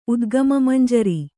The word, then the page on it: ಉದ್ಗಮಮಂಜರಿ: kannada